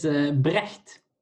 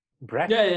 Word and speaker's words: Brecht: It's Brecht, but that's pretty difficult, I think, for like South American countries.